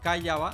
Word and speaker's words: Calle B: Así que pendientes este miércoles 8 de abril, 4 de la tarde, hora de Panamá, a través de la página de YouTube Calle B, Callaba, o sea, Calle B se escribe, México.